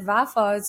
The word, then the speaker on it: Vafa: Yeah, well, that's actually one reason why my last name dropped short, because people see all those syllables in my last name and they're like, well, how do you even pronounce this? So we just dropped it short. It said Vafa, so we get rid of the Vafa-E at the end.